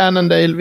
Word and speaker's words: Annandale: var det så fel uttal? nej, nej, nej, det heter Annandale.